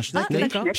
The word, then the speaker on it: Schneck: ça se prononce Schneck, oui, Un ah, Schneck, Schneck, Alors, je trouve.